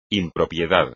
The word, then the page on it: impropiedad: espagnol